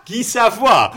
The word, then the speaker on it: Savoy: But it's S-A-V-O-Y. – Oh, bah oui, mais ça se prononce Guy Savoy.